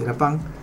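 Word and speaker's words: lapin: Conejo en lapín, en francés se dice lapín, se escribe lapín, dice lapán.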